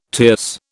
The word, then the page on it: tierce: British English